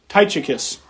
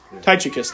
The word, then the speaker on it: Tychicus: Am I pronouncing that right? Does that sound right? Tychicus? I'm sorry? – Tychicus. – Tychicus, thank you.